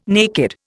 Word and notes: naked: Indian

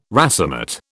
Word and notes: racemate: British English

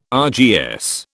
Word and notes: RGS: British English

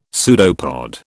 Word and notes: pseudopod: British English

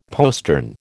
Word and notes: postern: American English

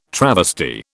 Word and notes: travesty: British English

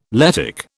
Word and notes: Lettic: British English

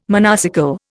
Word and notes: monocycle: Indian

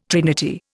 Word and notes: trinity: Indian

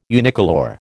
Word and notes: unicolour: American English

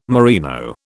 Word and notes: merino: British English

